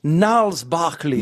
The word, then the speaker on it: Gnarls Barkley: C'était Gnarls Barkley. Voilà, vous avez la bonne prononciation.